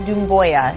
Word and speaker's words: Doumbouya: Sekou Doumbouya. He's like, Doumbouya.